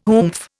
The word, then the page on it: oomph: Indian